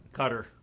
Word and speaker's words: Qatar: Is it Qatar? Qatar? How do you pronounce that? – Qatar.